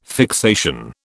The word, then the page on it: fixation: British English